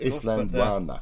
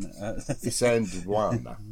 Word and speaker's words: Isandlwana: Oh, I've forgotten how to say it. – Isandlwana. – That's very good. But is it? Again, defeat, yes, it's got no L in. – Isandlwana.